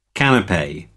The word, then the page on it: canape: American English